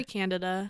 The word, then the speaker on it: Candida: Is it Candida or Candida? – We say Candida. – Okay. – We say Candida.